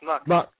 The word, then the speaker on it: Muck: Is it Muke, M-U-K? – No, you got it right, John. It's Muck.